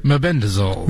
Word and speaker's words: Mebendazole: Mebendazole. – Mebendazole capsules, ivermectin cream, and hydroxychloroquine.